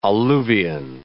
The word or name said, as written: alluvion